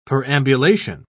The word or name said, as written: perambulation